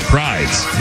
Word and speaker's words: Prydz: Is it Eric Prydz? – Yeah. It's Prydz, yeah. – P-R-Y-D-S. – I've heard it said both.